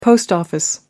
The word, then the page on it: post office: American English